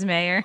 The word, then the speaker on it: Mayer: My last name's Mayer.